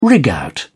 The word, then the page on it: rig out: British English